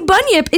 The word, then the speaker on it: Bunyip: Like B-U-N-Y-I-P. Bunyip. – Bunyip. It's a gross foot disorder. Cryptid. – It's super not, but we're going to get to it. So the Bunyip is a creature of Australian Aboriginal belief.